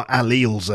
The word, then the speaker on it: allele: Oh, my alleles are hurting this morning. No, but it's a specific scientific term from genetic biology, and it's pronounced allele.